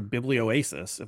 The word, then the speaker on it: Biblioasis: And the book is part of the Field Notes series, which is put out by the Canadian publisher Biblioasis, if I'm pronouncing that correctly. – Yeah, that's right.